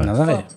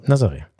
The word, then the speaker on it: Nazaré: Den da Nazaré, nochmal. – Nazaré. So. – Nazaré. – Einigen wir uns auf Nazaré.